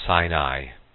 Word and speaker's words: Sin: And all the congregation of the children of Israel came into the wilderness of Sin, which is between Elim and Sinai. And actually it says it should be pronounced Sin, S-I-N.